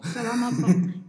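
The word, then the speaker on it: Salamat po: You brought with you the poem Salamatpo. – Salamatpo.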